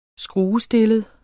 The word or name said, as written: skruestillet